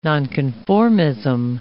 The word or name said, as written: nonconformism